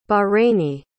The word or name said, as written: bahraini